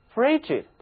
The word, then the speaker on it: frigid: 一个人要是对人非常冷淡，他也会使人家觉得身上从头到脚像是被泼了一盆冷水似的，所以 frigid 它也有冷淡的意思，冷，冷淡的， frigid。这里的字母 G 它读作 G Frigid。